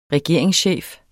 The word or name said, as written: regeringschef